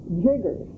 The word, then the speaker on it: chiggers: it's spelled C-H-I-G-G-E-R-S, but we called them jiggers, J-I-G-G-E-R-S, and I guess they did make us jig.